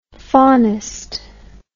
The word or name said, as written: faunist